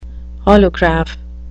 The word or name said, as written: holograph